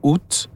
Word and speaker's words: août: Mais si tu fais une recherche, puis j'ai fait ça justement parce que quand quelqu'un disait 20 et août, et puis ça dit que la façon de le prononcer, c'est sans prononcer le T, au moins que tu fais une liaison à un autre mot. Comme si tu dis 21, tu vas prononcer le T, tu vas pas dire 20 et 1. Mais sans ça, le T devrait pas être prononcé.